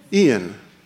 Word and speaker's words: Ian: All right, so here, well, it's probably been a little bit more than a week that warnings for the storm, is it Ian or Ian? Ian. How come they don't spell it E? – The I is not pronounced like an I, it's pronounced like an E.